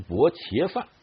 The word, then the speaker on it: 薄伽梵: 那么我在学佛的时候呢，经常会在佛经里看见这个词啊，比如说很多经啊，佛经一开篇就是如是我闻啊，一时啊薄伽梵啊，那个时候呢，我们在佛教界里边，他们读成薄伽梵啊，一时薄伽梵啊，住王舍城，鸠峰山顶，与大比丘啊，众千二百五十人聚啊，经常会看到这样的句子。